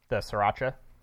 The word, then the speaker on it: sriracha: Sriracha. – You kind of blend the S and the R. – But isn't it S-I-R? – S-R-I-R. – S-R-I-R. – Sriracha.